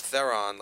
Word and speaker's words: Theron: I believe it's Theron, like Heron, like the bird.